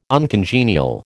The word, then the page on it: uncongenial: American English